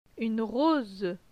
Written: Une rose

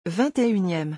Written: 21eme

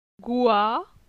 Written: Goi

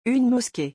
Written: une mosquée

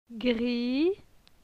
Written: Gri

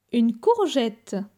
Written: Une courgette

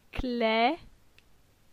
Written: Cle

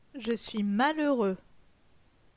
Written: Je suis malheureux

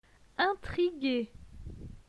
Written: Intrigue

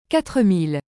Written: 4000

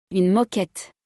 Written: Une moquette

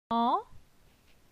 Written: Zan

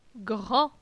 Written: Grand

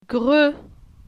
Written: Gre

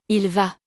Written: il va

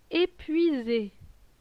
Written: Epuise

